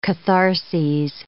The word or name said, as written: catharses